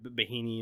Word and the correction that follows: Bieniemy: in Kansas City or however you say his name. – You're talking about Bieniemy. – Yeah, Bieniemy.